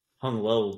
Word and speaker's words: Hung Low: But the name was like Hung Long? – Hung Low, bro. – Hung Low, player. All right, low? Guys. – Damn, man. It's pronounced low.